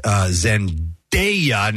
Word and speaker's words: Zendaya: Laverne Cox, Zendaya.